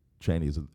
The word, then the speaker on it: Cheney: Bush and Dick Cheney, it's really supposed to be pronounced Cheney, but everybody says Cheney, were running for president and vice president.